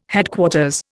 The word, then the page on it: Headquarters: Indian